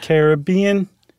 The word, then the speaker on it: Caribbean: and this conch mollusk, the queen conch is in the Caribbean, or is it Caribbean? Because I heard someone say it once. – It's the Caribbean. Yeah, you have to say it like you're about to sing the Billy Ocean song.